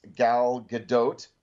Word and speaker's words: Gadot: This stars Gal Gadot, and I think I am finally pronouncing her name correctly. It's not a French name. It's a Jewish name, as the main character.